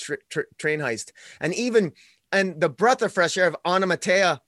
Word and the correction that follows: Onomatopoeia: Onomatopoeia? – Onomatopoeia.